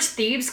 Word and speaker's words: Thebes: It's S-E-M-E-L-E. The princess of Thebes. T-H-E-B-S. – Thebes. – T-H-E-B-E-S. – It's Thebes. – Thebes. I always think the French, like, Tim River. I only remembered Thebes because every time that I looked it up for Hercules, the movie, it's called Thebes.